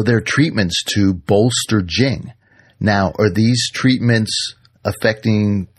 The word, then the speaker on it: Jing: So they had concepts like Jing, and that's spelled J-I-N-G. We transliterate it as Jing.